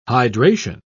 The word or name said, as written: hydration